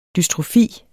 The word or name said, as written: dystrofi